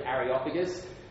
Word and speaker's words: Areopagus: That's how you pronounce that word, Areopagus.